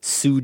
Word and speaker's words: Tsu: And if connections to Zombieland still aren't clear, here's Gordon Danford writing in from Sue Japan. Do you think I'm saying that right? TSU? – That was perfect.